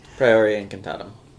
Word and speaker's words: Priori Incantatum: Priori Incantatum. – Sure. – That's right? Sure. Is that right, Latin lady? – Priori Incantatum.